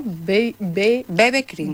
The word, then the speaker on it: BB Cream: BB Cream, lo he dicho bien, ¿no? – BB Cream. – BB Cream, eso es.